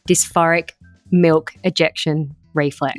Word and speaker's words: dysphoric milk ejection reflex: dysphoric milk ejection reflex.